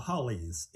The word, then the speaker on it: haoles: And also, how do you pronounce the word that they call the Euro-Americans? – The Hollies, H-A-O-L-E-S. – The Hollies. Okay.